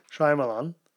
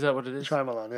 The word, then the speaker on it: Shyamalan: Shyamalan. Easy. – Is that what it is? – Shyamalan, yeah.